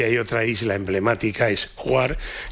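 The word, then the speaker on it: Hvar: No sé bien cómo se pronuncia, Juar, ¿no? Es... – una isla que se llama, se escribe H-V-A-R, y yo creo que se pronuncia como Juar o algo así, pero claro, este sonido de cigarras me lleva a eso, al sí.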